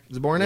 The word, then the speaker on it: Zbornak: Zbornak? – Yeah.